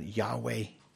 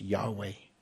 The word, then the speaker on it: Yahweh: And of course, we see this word, when you see in your Bibles, Lord, all capitals, L-O-R-D, it's the holy sacred name of God, Yahweh. Is how we tend to pronounce it. It's just the vowels. I'm sorry, just the consonants without the vowels. So, but scholars think it would be pronounced Yahweh, the covenant name of God, the self-existent, eternal covenant God.